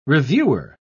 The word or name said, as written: reviewer